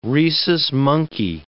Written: rhesus monkey

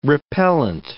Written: repellent

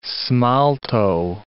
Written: smalto

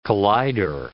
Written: collider

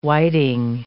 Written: whiting

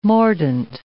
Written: mordant

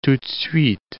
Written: tout de suite

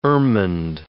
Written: ermined

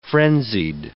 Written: frenzied

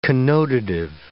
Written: connotative

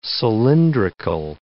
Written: cylindrical

